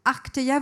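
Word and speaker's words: Arkteia: Je me suis permis de réinsister sur la prononciation de Arkeia pour vous expliquer quelque chose. Arkeia, vous reconnaissez la même racine étymologique que dans Arctique.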